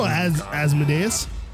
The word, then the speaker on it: Asmodeus: The demon Asmodeus was trapped inside. – Oh, I know Asmodeus. – This has happened before. – That's how they say it on Supernatural.